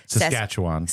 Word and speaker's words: Saskatchewan: Saskatchewan.